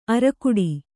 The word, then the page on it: ಅರಕುಡಿ: arakuḍi